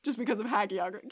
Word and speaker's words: hagiography: The word of the day today is hagiography. It's spelled H-A-G-I-O-G-R-A-P-H-Y.